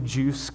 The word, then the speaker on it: Juce: juice cleanse drink, but it was called I think pronounced juice, but it was J-U-C-E, so without the I, especially cleansing.